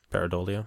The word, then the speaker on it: pareidolia: And you believe in what, confirmation bias and pareidolia? How do you say it? – Pareidolia.